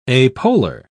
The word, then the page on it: apolar: British English